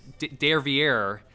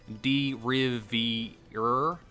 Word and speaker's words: Derivière: Derivière. – Or if you're like me for years upon years up to this point, you pronounce it the way it looks like as Derivière.